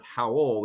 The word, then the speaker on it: Howell: Howell is spelled H-O-W-L, like Howell at the moon. My name, last name on LinkedIn is Mark Howell, which is H-O-W-E-L-L.